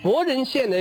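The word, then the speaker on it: 柏人: 而这个百数的百，它还有一个读音，就是那个伯，哇，上古时代伯跟那个破，逼迫的迫，这两个读音呢非常近，所以这个伯人县呢也可以读成破人县。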